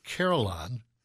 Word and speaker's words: carillon: And I teased at the end of that first segment that we're going to talk about something called a carillon. Am I saying that right? – Yes, sir.